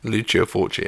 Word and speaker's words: Lucio Fulci: Lucio Fulci. I love his stuff. House by the Cemetery. – Lucio Fulci, yeah.